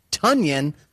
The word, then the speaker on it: Tonyan: His name is pronounced Tonyan, as in rhyming with Funyan. Apparently, everyone has been calling him Tonyan for a long time, and he just didn't feel like correcting people. He's too nice. But it's Tonyan.